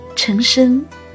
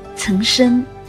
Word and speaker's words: 岑参: 这个有点拗口的名字，姓是平舌音，名又是多音字，一不小心就会读成陈参、陈参、岑参，而正确读音应该是岑参。